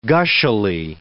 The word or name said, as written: gushily